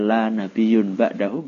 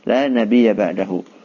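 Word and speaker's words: la nabiyya ba'dahu (لا نبي بعده): Bacanya jangan keliru. La nabiyya. Nah, kalau bacanya la nabiyyun ba'dahu bahaya. Karena nanti maknanya akan berubah, nak.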